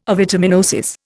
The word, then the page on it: avitaminosis: Indian